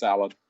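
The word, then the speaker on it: Allin: Cody versus Darby, I want to say All In, but is it Allen? – It's Allen.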